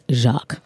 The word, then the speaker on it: Jacques: Jacques, like French? Jacques. – Like Webster. – Jacques. – Like literally said and pronounced like Jacques.